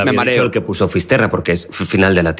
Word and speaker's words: Fisterra: Entonces llegó a Finisterre. ¿Se dice así? – Fisterra. – Fisterra. Es como se ponen los gallegos también, en fin.